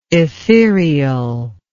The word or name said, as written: ethereous